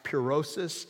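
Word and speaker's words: pyrosis (πύρωσις): Now, the Greek word for test is pronounced pyrosis, and it's where we get words like pure or purify.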